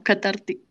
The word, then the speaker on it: catártico: Catárquico, catartito, catártico, catártico Cat no, catártico.